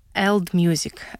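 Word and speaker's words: AELD Music: но в то же время читается и произносится это так, как old music.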